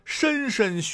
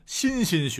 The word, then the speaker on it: 莘莘: 最后，最后一个大家伙可能也很熟，不过呢，经常会选错、念错，这就是莘莘学子，千万不要再念成莘莘学子了。